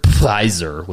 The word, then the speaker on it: Pfizer: You can disagree with me all you want, but that is literally every commercial now on almost every single entertainment platform is a big Pfizer with the silent P.